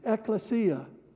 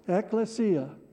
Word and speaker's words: ekklesia: I don't normally do this, but you can repeat after me. Ecclesia. Ecclesia.